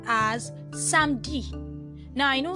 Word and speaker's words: samedi: In most cases, the letter E is being omitted when pronouncing. So that's why the French speakers hardly stress themselves to say samedi. Instead, they say samedi.